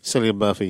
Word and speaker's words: Cillian: So, it's set in 1920s Birmingham, follows Tommy Shelby, played by Cillian, is that how you pronounce his name? – Cillian Murphy, yeah.